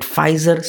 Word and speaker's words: Pfizer: I think the P in the Pfizer is silent. I'll just call it Pfizer.